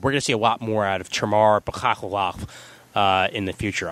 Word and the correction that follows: Bekmambetov: I can't pronounce his name. I'm sorry. It's just Bekmambetov, I think, or something. Tamar Bekmambetov. – Tamar Bekmambetov.